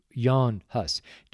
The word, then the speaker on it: Hus: Are John Wycliffe as W-Y-C-L-I-F-F-E, John Wycliffe, and they pronounce it John Hus, but it's actually Jan Hus, J-A-N-H-U-S, Jan Hus, and John Knox, K-N-O-X.